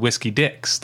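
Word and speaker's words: Whiskey Dick's: They're always very clear to pronounce it in such a way that it's like, we're referring to a guy named Whiskey Dick, not the phenomenon of not being able to get it up because you're too drunk.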